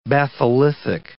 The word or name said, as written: batholithic